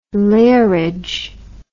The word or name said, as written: lairage